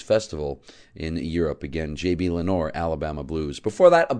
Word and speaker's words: Lenoir: Although normally it's pronounced Lenoir, he pronounced it Lenore.